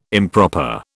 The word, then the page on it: improper: British English